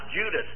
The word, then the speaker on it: Judas: It's pronounced Judas. That's the way it was pronounced at a later time.